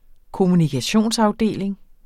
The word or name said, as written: kommunikationsafdeling